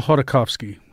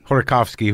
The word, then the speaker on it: Khodorkovsky: Mikhail Khodorkovsky. – Khodorkovsky, who was an oil magnate, an oil oligarch.